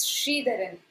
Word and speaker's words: Sridharan: Sure, it's Sridharan.